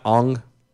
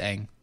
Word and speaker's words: Aang: Are we sure it's Aang and not Ong? – Yeah, it's Aang.